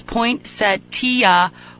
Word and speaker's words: poinsettia: He is saying that I have researched this in the past, and it is poinsettia, four syllables, is the proper way.